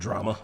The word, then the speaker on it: Jrama: I had a neighbor named Drama, spelled with a J. Shout out to the homie. – J-R-A-M-A? – Yes. – Drama.